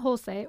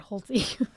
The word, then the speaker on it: Holshay: Raymond Holshay, or Americanized version, Raymond Holsey, a lot of people pronounce it, was born in 1866 in Germany.